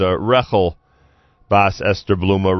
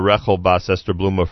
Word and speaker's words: Rechel Bas Esther Bluma: By the way, the proper pronunciation of the name that I gave, let me just do this correctly, is Rechel Bas Esther Bluma. Rechel Bas Esther Bluma for Refuah Shleima.